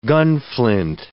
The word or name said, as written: gunflint